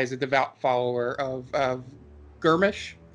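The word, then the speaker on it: Gruumsh: I think Dan would be able to pronounce it better than I would. – I wouldn't. – Okay. – I was just saying, I think that's Grumsh. – Yeah. Yeah.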